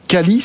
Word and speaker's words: Qualees: Vous êtes euh, président de Calis, c'est comme ça qu'on prononce Qualis. Ou, Qualis, d'accord.